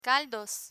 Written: caldos

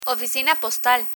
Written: oficina postal

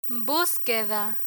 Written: busqueda

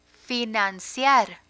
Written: financiar

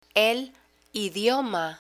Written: el idioma

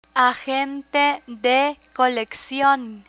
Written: agente de coleccion

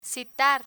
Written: citar

